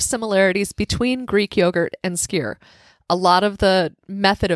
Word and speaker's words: skyr: The thick, like, is it skyr? – Yes. – How you pronounce it? – It is skyr. Yep.